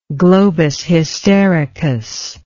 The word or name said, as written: globus hystericus